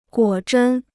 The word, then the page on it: 果真: guǒ zhēn